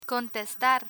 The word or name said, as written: contestar